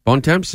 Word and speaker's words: Bontemps: Bontemps, is that how you pronounce his name? – Yes.